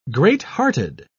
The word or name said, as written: greathearted